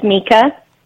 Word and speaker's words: Mika: Her name is Mika. We spell it M-I-K-A, but it's pronounced M-E-E-K-A.